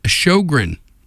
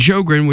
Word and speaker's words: Sjowgren: Today's subject is Sjogren. And Sjogren, which is spelled S-J-O-W-G-R-E-N, they're a mixed-gendered surf-pop trio based in the San Francisco Bay Area.